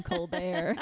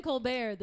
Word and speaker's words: Colbert: Oh, because he's like, I'm about to serve you the tea because the tea is silent in Colbert. – It was so, because in Colbert the tea is silent.